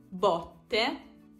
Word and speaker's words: botte: Botte, che sono le percosse, i colpi, rispetto a botte, che indica invece il barile.